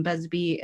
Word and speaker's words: Busby: Busby, and then under the same name on Facebook.